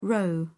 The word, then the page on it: roe deer: British English